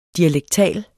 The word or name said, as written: DIALEKTALT